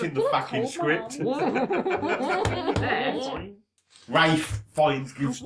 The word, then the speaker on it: Ralph: If he wants to pronounce Rafe... – You're an inanimate fucking object! – We've all got fucking suicidal thoughts. I'm fucking suicidal. – You take that back about my camp kids. – If he wants me to pronounce his name Rafe, he can spell it fucking Rafe. But when he starts spelling it Ralph, A-R-L-P-H, no.